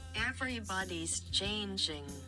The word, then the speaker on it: Everybody's Changing: ¿Cómo se pronuncia esto? A ver. – Everybody's changing. – Ah, está bien, lo dije bien. Everybody changes. ¿Es así? – Everybody's changing. – Changes. – Changing.